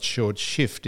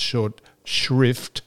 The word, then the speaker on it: short shrift: Now, that is not short shift, incidentally. It's short shrift.